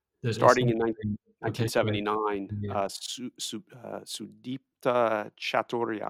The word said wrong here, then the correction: Bhattacharya: I butchered the name. – I know University of Chicago. Do you know the proper name of that person in 1979? – I don't remember exactly the pronunciation. Yeah. Bhattacharya, maybe? – Yes, Bhattacharya. Very good.